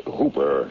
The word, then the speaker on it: Houper: The name's Hooper, spelled H-O-U-P-E-R, but pronounced to rhyme with Cooper.